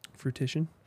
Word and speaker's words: fruition: Truition, whatever it's called. – Fruition? – Fruition. – Partition? – Four syllables, man. It's a lot for me. – I'm tired, too. – Fruition. No, it's three.